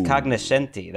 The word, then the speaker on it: cognoscenti: It has nothing to do with tennis, although I did enjoy the tennis analyst said that the tennis cognoscenti, so maybe Ooh. It's cognoscenti, that was the British pronunciation, pointed out.